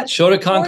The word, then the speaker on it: Shotokan: Shotokan, right? Am I saying it right? – Yeah, Shotokan karate.